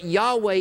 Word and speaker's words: Yahweh: It's the four continents, consonant, not four continents, by the way, four consonants, Y-H-W-H. We've lost the pronunciation. We don't really know how it's pronounced, but Yahweh is the closest that you can get.